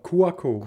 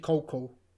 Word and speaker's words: Cuoco: Ich habe wirklich Kaylee wie spricht man den Nachnamen auf, aus? Ich Coco. – Kuako. Kuako. Kuako. – Laut, laut Wikipedia, laut der Lautschrift, ist es wirklich Coco. Co oh. Coco. Also das U, das U spricht man gar nicht aus.